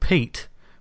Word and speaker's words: Peat: There's a basically a sort of swamp sort of monster child who's called Pete, which is spelled P-E-A-T, which I thought was brilliant.